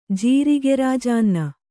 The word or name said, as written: ಜೀರಿಗೆರಾಜಾನ್ನ